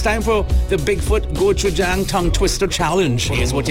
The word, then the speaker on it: gochujang: Okay, three easy syllables. Gochujang. It's G-O-C-H-U-J-A-N-G.